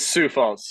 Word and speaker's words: Sioux Falls: And so how do I, it's not Sioux Falls. Like, how do I actually pronounce it? – It's Sioux Falls.